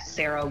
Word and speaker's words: Sarah: You can find me on all the social medias at Sarah Wood Writer, S-A-R-A-H-W-O-D, Writer, like writing things down.